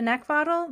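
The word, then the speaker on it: Neckvatal: You can find me on LinkedIn and YouTube under Brenda Neckvottle. That's my last name is spelled N-E-C-K, like the thing you want to choke. V as in Victor, A-T-A-L.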